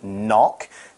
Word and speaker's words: knock: To explain this idea, let's talk about the word knock. It's got a K at the start, a silent K, so it's K-N-O-C-K. Mm-hmm. K-N-O-C-K.